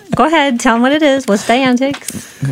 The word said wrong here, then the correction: Dianetics: I think it's pronounced Dianetics. – Dianetics.